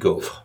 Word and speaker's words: gaufre: So have you ever heard something called a gaufre? I think it's pronounced. – In French, it would be gaufre.